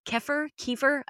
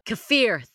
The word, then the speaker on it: kefir: But if you want to go for a probiotic in food or live yogurts, Kefir, I always say it wrong again. – Kefir, thank you.